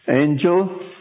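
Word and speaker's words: Ángel: But this is the way we pronounce it. Now that's bad, that's really bad. Because it tells you the type of angel I am. But it's really, you see, we put the stress here.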